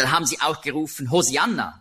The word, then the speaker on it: Hosianna: Hosianna ist eben die griechische Aussprache von Hebräisch Hosianna. Das kommt daher, im Griechischen gibt es keinen Sch-Laut.